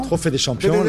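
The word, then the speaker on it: Trophée des Champions: As Paris Saint-Germain win the coveted Trophée de Champion. – Trophée de Champion. – Did I pronounce it correctly? – Perfect. Perfect.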